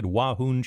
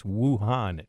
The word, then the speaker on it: Wuhan: I believe I said Wuhan, China. It's Wuhan, China.